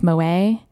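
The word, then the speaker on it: Moët: For the record, it's pronounced Moet. Lots of people do actually call it Moet. I'm hearing more and more. I hear more people call it Moet now than actually say Moet.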